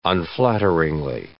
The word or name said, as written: unflatteringly